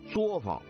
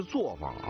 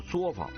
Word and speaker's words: 作坊: 有一个词儿叫作坊啊，不是作坊啊，我也经常听到有人念作坊啊，是不对的啊，应该是念作啊，就是作坊啊。